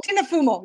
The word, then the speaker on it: Fumo: Pretty close. Yeah, it's Tina Fumo. Yeah, it's Italian.